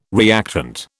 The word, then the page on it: reactant: British English